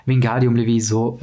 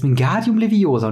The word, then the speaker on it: Wingardium Leviosa: Das heißt nicht Wingardium Levioso, äh, es heißt Wingardium Leviosa und nicht Leviosa. So. – Aber und, hier ist es anders beschrieben. Genau also hier, hier ist eine größere äh, Betonung auf das Ga, also Wingardium Leviosa.